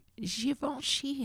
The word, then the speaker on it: Givenchy: To politely climb out of this giant Givenchy handbag. – Thank you for pronouncing it correctly. Your French is flawless.